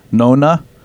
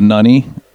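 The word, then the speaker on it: Nonna: It can be pronounced Nunny, or it can be pronounced Nona, but we went with the Nunny, and either way is correct.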